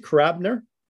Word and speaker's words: Karabiner: Is that how you pronounce it, Karabiner? – It's actually Karabiner. If if you speak in Russian, it's like Karabiner. – Okay. – The accent is on the I.